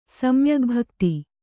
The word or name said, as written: ಸಮ್ಯಗ್ಭಕ್ತಿ